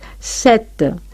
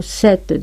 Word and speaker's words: cette: Tenemos el demostrativo masculino singular, se, y femenino singular, set. Cuando la palabra que acompaña el masculino singular, se, empieza por vocal o por H muda, se añade a este se una T. Ahora, en cuanto al femenino, siempre es set, empiece o no por vocal la palabra siguiente. Digo cette dame, cette charpe.